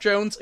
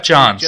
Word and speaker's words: Johns: Also, I want to say, I just realized I did mispronounce his name as Geoff Jones. It is Geoff Jones. That wasn't me making fun. – Geoff Johns.